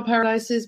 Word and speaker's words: Paradise: So it's M-I-C-H-E-L-E, just one L. And my last name is Paradise, just the way it sounds. P-A-R-A-D-I-S-E.